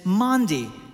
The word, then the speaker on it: Maundy: So what is it that Maundy Thursday means, right? It's not a mispronunciation of Monday. It's Maundy.